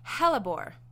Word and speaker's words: hellebore: Okay, it is hellebore.